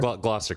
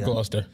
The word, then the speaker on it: Gloucester: Gloucester County. – Gloucester County. I thought there it was Gloucester. – I added all these extra syllables and shit.